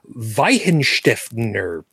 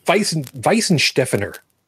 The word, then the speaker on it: Weihenstephaner: Weihenstephaner. – Close enough. – Weihenstephaner.